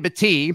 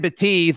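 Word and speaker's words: Battie: And it's interesting because there was another running back that came in, Brian Battee. It's not Batty. Sorry, I've been saying his name wrong. Brian Battee, the South Florida back.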